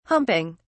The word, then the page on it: humping: British English